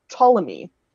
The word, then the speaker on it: Ptolemy: So you have like Ptolemy, which is pronounced with a P in there.